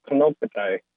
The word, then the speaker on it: Kanakdai: Well, I think I explained it earlier, but from the southeastern Pomo, Kanakdai, the pronunciation is Kanokatai, and Kano means mountain, and Katai is the word for, it's a word for an older woman, but plural, more than one older woman.